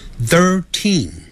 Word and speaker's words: thirteen: 十三 thirteen thirteen，所以 thir 有一个 th，舌头就要上齿跟下齿轻轻咬住舌头吐出来 third，然后 third 赶紧往里头说啊 thirteen，这个有轻重音之别，不要念成 thirteen，那个 teen 要重读，也就是读出来的时候声音比较重一点 thirteen。